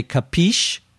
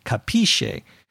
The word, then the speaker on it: capiche: It comes originally from the Italian, but Americans give it their own American pronunciation. We say capiche. The Italian pronunciation, I think, would be capisce, but we say capiche.